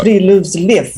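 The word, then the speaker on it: Friluftsliv: Y primero, eso se refiere, déjame ver si lo pronuncio así como rey, Free love live. – Eso. – Free love live. – Muy bien, profesora, muy bien.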